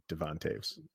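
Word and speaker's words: Devon Toews: I was corrected in saying he pronounces it Devon. Don't know. I guess that, so I've tried to switch my way of saying it into Devon Taves.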